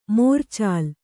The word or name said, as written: ಮೋರ್ಚಾಲ್